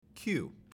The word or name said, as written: queue